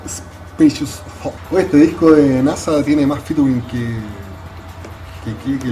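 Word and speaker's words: N.A.S.A.: No, cabrón, si ellos lo pronuncian como NASA.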